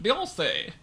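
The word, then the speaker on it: Beyoncé: Beyonce. She's French. – Beyonce. – That's how you pronounce it.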